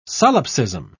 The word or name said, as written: solipsism